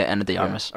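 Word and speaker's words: Ana de Armas: Yeah, and I think I'm saying that right. Anna de Armas, am I? – Yeah, Anna de Armas.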